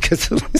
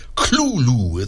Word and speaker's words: Cthulhu: He claims to have had a pronunciation for it, and it's more like Cthulhu. It's that the TH is not really a TH. It's kind of a guttural L, but...